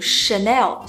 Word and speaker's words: Chanel: 哦，香奈儿读 Chanel，